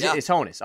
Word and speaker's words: Honus: So in 1914, on June 9th, Honus Wagner became the second player in Major League Baseball history to record 3,000 hits. Am I saying that right? I feel like... – Yeah. – It's Honus.